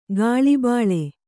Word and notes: ಕುಳಿಬೀಳು: kannada

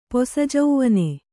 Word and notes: ಪೊಸಜೌವನ: posa jauvana

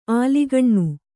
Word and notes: ಅಲಕ್ಕನೆ: alakkane

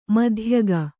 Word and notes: ಮಧ್ಯಗ: madhyaga

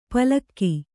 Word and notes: ಪಲಕು: Kannada

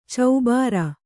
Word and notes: ಚೌಭಾರ: caubhāra

